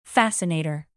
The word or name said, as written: fascinator